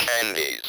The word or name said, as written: candies